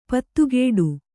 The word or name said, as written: ಪದಗುಡು